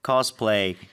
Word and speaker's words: cosplay: In Korea, people say cosplay. – Yeah. – In English, Americans say cosplay. – Cosplay. Yeah, play까지 play. – 해야죠. – Yeah, cosplay. – 프레 이렇게 끝내면 안 되는 거죠.